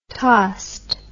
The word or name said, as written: tossed